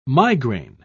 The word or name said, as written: migraine